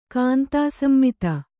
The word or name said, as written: ಕಾಂತಾಸಮ್ಮಿತ